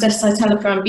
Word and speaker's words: escitalopram: For what it's worth, I think you pronounced escitalopram beautifully.